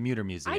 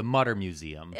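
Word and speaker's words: Mütter: Now, you pronounced it correctly, the Muter Museum. – I did pronounce it No, correctly. I'm saying you did, but a lot of people call it the Mutter Museum. – Both are fine.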